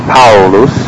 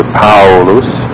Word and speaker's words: Paulus: For instance, in the name of the great apostle of the Gentiles, Paulus, it is pronounced AU, as the A sound, A, the U sound, U, AU, AU, AU, gliding together, Paulus.